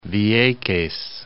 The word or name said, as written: Vieques